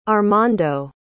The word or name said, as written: armando